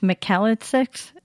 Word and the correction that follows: Melchizedek: Priesthood? – Yes, it is Melchizedek Priesthood.